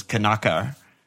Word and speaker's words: Knockaert: You know how they always say Knockaert? – Yeah. – That's based off of the French pronunciation, which is Kanaka. Like, no, you don't, don't pronounce the T, which is uh, interesting.